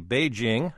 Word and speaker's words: Beijing: Okay, John, you and Carsey taught me to properly say Beijing.